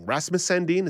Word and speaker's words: Rasmus Sandin: Rasmus Sandin, is that the defenseman? – Yep, you got it.